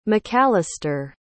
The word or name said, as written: mcalister